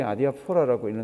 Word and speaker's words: 아디아포라: 아디아포라. 액센트는 아디아포라입니다. 여기 액센트가 여기 갑니다. 우리말로 읽어보면 그게 아디아포라라고 읽는데 이 말은 성경에는 나오지 않습니다.